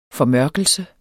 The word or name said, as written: formørkelse